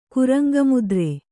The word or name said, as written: ಕುರಂಗಮುದ್ರೆ